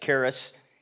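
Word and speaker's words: charis (χάρις): And no, that's not how you pronounce it, but this is, it's charistos, charis. You're familiar with that word, aren't you? That means grace. Charis is grace, and then it's charistos, T-O-S.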